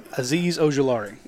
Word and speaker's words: Azeez Ojulari: And, you know, from what I hear, how do you pronounce that again? – Azeez Ojulari. – Attaboy.